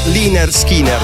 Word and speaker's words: Lynyrd Skynyrd: domande riguardo la pronuncia, perché qualcuno arriverà sicuramente al nostro WhatsApp a dirmi: no, si pronuncia così. Il loro primo album era proprio Pronounced Liner Skinner.